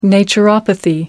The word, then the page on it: naturopathy: American English